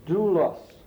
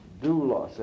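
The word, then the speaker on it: doulos (δοῦλος): It's doulos. Doulos. And that little squiggly at the beginning is a delta, and it's pronounced like a D. So it's sort of like D-O-U-L-O-S.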